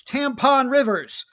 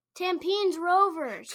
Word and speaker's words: Tampines Rovers: And our other team is Tampon Rivers. – Hey, also not how you pronounce it. Tampines Rovers.